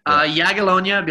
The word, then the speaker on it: Jagiellonia: Jagiellonia Bialystok. – Jagiellonia Bialystok.